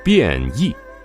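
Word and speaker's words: 便宜: 变异两字读音为变异。 1958年，周恩来总理在此用餐，赞不绝口之余，以便利人民、一世一家 8个字为字号做了诠释。 – 变异坊创建于公元1416年，明永乐14年，距今呢已经有 605年的历史了。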